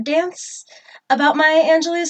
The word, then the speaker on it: Angelou: Maya Angelou, which I always thought was Maya Angelou, but I guess it's Angelou. – It is Angelou, but I've heard it pronounced both ways, so it's kind of like...